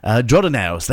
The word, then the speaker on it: Jordanaires: Jordanaires. Jordanaires, that's how you say it.